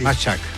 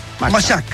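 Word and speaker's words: Majchrzak: Machak. – ¿Así se dice? – Sí. – Camil Machak.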